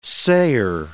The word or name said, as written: sayer